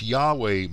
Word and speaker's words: Yahweh: There is evidence Yahweh might have correctly been pronounced like our state Iowa, named after an Indian tribe.